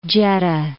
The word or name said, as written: Jeddah